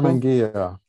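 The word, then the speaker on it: Karmann Ghia: And I don't know if this is real or fiction, but it was a 1969 Carmen, is it pronounced Ghia convertible? – Carmen Ghia.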